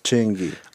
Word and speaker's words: Chingy: Yeah, you said it wrong. Chingy.